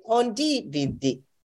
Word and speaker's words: condividere: The acento in, is, is on the second syllable. Mm-hmm. – So I mm-hmm. say condividere.